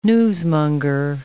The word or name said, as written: newsmonger